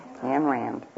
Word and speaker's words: Ayn Rand: A-Y-N. Ayn Rand.